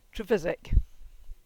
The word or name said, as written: Trevissick